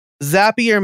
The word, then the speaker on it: Zapier: Zapier makes you happier, is what people say to remember the pronunciation.